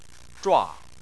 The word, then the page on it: zhua: 4